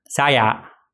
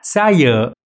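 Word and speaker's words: saya: إذا سألت أي شخص عربي وقلت له كيف تقول كلمة أنا بالماليزي، سوف يقول لك سايا، وهذا هو النطق الغلط. النطق الصح سايا. انظر إلى الفرق، سايا هناك نوع من الطلوع والنزول في هذه الكلمة.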